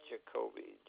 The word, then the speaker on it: Jakobi: It's William Jacoby, J-A-K-O-B-I.